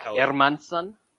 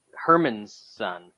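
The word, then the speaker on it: Hermansson: You need to listen to more of our boy Aaron Bronstetter's podcast, because he's got the correct pronunciation of Jack Hermanson. – Hermanson? – Yeah, Hermanson. He's like, he's not Herman's son. He's Michael Jordan's son, actually. It's the Airman. It's his son.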